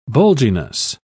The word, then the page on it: bulginess: British English